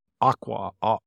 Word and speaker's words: Auca: The name of it's spelled A-U-C-A. It's pronounced Aqua.